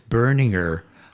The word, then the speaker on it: Berninger: In Germany, you would say Berninger. – Berninger. Berninger. – But in English, it's the same, but you just pronounce, add an R after the first vowel.